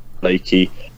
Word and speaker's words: Blakie: Ask any questions or anything you have concerning Barnet at Jonathan Blakey, B-L-A-K-I-E, is how you spell it.